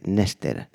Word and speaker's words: N3STER: Nester, que se escribe como de la siguiente manera: N3STER, Nester.